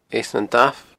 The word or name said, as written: Eas nan Damh